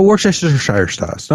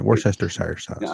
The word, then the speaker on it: Worcestershire: but I call it Worcestershire sauce. – Oh, Worcestershire sauce. No, man, that is how you pronounce it. Worcestershire sauce. – I know that it's a peculiar way to pronounce it, but that's okay.